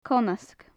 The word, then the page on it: conasg: gaelic